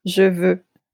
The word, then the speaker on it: Je veux: How do you say that? – Je veux.